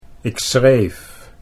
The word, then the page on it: ik schreef: Dutch